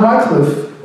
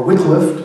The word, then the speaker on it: Wycliffe: Even further back, in the 1300s, John Wycliffe, or Wycliffe, depending on how you say it, had translated the Bible from Latin to what was called Middle English.